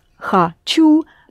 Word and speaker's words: хочу: You pronounce хочу, but you actually spell хочу. There's о there, which is unstressed. That is why you pronounce it as а, but it's о.